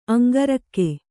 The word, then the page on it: ಅಂಗರಕ್ಕೆ: aŋgarakke